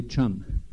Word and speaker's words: chum: Et tous les hommes sont des chums. Ça s'écrit C-H-U-M, mais ça se prononce comme s'il y avait un T au début, un chum.